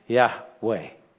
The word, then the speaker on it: Yahweh: And a little bit later, God abbreviates that to those four letters, which normally are thought to be pronounced Yahweh.